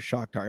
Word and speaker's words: Shakhtar: Um, so we're we've got the deal done with Lyon, but then we also will have to figure something out with Shakhtar.